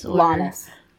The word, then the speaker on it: Llanes: Llanes.